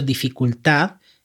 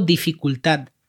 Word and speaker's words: dificultad: yo digo dificultad, como una T al final.